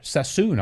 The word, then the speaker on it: Sassoon: Sassoon, I think it is actually pronounced.